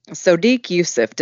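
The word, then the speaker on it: Sodiq Yusuff: Juice says, Sadiq Youssef, did I say that right? Juice is, yes, challenging me. – Sadiq, okay. Youssef. Yep.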